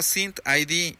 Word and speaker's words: SynthID: Te lo voy a deletrear. S-Y-N-T-H-I-D. Synth. Haz de cuenta como si junto dijera Synthid. Pero contigo es S-Y-N-T-H-I-D.